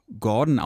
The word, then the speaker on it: GRDN: Er hat nämlich alle Vokale aus seinem Namen entfernt und heißt jetzt nur noch Gordon ausgesprochen, aber G-R-D-N. Punkt.